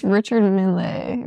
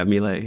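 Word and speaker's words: Richard Mille: It's Richard Milley, right? – Yeah, Milley.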